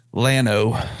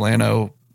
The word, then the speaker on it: Llano: And so they went to Llano, which always pains me to say, but that's how you say it. So they went to Llano and kind of found a spot away from everybody.